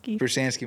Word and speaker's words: Prestanski: Did I say it right? – Prestanski, my bad.